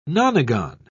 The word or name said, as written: nonagon